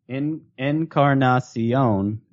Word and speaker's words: Encarnacion: All right, how many syllables in Encarnacion? – Encarnacion is Encarnacion. Five.